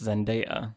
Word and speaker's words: Zendaya: Zendaya. – Zendaya. – Although we have a friend that worked with her that said it's Zendaya.